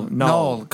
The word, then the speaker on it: Knull: Null, K-N-U-L-L. – But yeah, way to pronounce the K, you dumb shit. But it's Null, yeah. Knull.